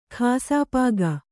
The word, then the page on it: ಕ್ಷಪಕ: kṣapaka